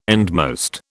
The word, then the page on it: endmost: British English